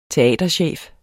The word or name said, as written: teaterchef